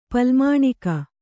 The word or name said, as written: ಪಲ್ಮಾಣಿಕ